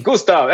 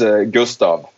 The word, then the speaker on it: Gustav: Gustav. It's very correctly. It's Gustav. We got very, I mean, here in Sweden, it's pronounced Gustav, but it's like Gustav.